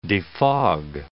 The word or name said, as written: defog